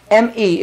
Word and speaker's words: me: M-E is pronounced like me.